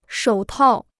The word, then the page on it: 手套: shǒu tào